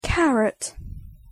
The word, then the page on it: carrot: English